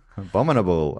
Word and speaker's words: abominable: I just have to imagine a bomb inside a bull. – Abominable.